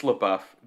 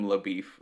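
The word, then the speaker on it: LaBoeuf: It's LaBeouf. They call him LaBeef the whole movie. And yeah, I get why they do it, but the entire movie I spent the time going, they're wrong. They're saying it wrong.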